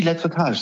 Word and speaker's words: Lettretage: Tom, habe ich das überhaupt richtig ausgesprochen? – Die Lettretage, sehr gut.